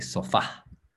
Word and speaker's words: sofá: The accent is on the A, sofá.